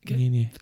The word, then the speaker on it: ngenge: Yeah. Nene. N-G-E-N-G-E.